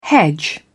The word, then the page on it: hedge: British English